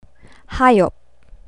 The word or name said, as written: hayop